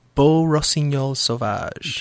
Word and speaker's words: Beau Rossignol Sauvage: How do you pronounce that one more time? – Beau Rossignol Sauvage.